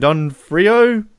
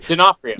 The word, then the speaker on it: D'Onofrio: Don Frio? I can't even say his name properly. – D'Onofrio.